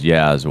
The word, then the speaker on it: Yas: Yeah, that's when I order a pizza and I say my name is Yaz, Y-A-S, people always write down W-A-S.